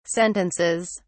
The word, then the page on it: sentences: British English